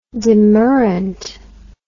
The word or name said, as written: demurrant